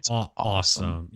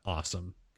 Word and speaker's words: Osom: A bunch of their former staffers, though, created a new company called I'm going to assume it's pronounced awesome. Yeah. So O-S-O-M. And we're going to just pronounce that awesome because that's how they've sort of referred to it.